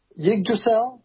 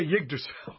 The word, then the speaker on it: Yggdrasil: Help me out, guys. – Yggdrasil? – Okay, Yggdrasil, of course.